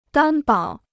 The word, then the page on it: 担保: dān bǎo